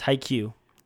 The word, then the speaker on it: Haikyuu: How do you say it? – Yes, Haikyuu.